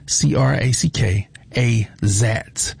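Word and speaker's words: Crackazat: I always say it wrong. It's Crack, C-R-A-C-K, A-Zack. A-Z-A-T. That's C-R-A-C-K-A-Z-A-T. I think that's Crack. Yeah, Crack-A-Zack.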